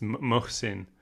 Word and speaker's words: Mohsin: Today we're going to be talking about the book The Reluctant Fundamentalist by Mohsen Hamid. Why'd you laugh? Is that not how you pronounce his name? – I think you swapped the H and the S. It's Mohsen.